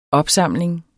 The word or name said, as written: opsamling